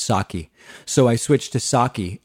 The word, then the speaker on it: Psaki: It's Jen Psaki with a soft A instead of Psaki. Jen Psaki. Bye-bye.